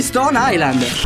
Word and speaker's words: Stone Island: Di Jim, che c'è la questi jeans della Stone Islands. – Stone Islands. – Ma perché scrivono? Scrivono come si legge. – Si chiama così, Stone Island.